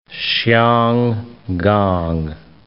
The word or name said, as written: Hsiang Kang